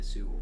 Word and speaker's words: Tetsuo: Am I pronouncing that right? – Tetsuo. We've seen Akira.